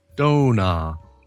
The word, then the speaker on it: dona: And dona means donut and is pronounced dona.